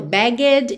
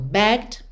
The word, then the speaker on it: begged: Primeiro, você reparou que esse é da terminação ed que a gente acrescentou, não é pronunciado. Eu não falo begged e nem borrowed, ok? Eu falo begged e borrowed.